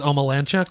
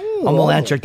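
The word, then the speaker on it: Omielańczuk: I think it's Omelanchuk. – Omelanchuk.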